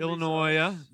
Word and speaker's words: Illinois: Washington, Illinois. The S is silent.